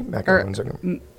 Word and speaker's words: macaron: National Macaroons Day. – Macaron.